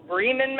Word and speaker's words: Bremen: Bremen would be the German pronunciation of that, as opposed to Bremen.